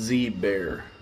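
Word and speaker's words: Zbaer: And how do you pronounce that? – It's Z-bear.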